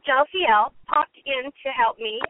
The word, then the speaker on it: Jophiel: I did a bit of investigative work, and I found that the name Jophiel, pronounced Jophiel, means beauty of God.